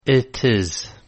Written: it is

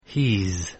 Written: he's

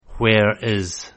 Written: where is

